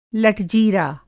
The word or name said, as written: लटजीरा